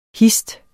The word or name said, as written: hist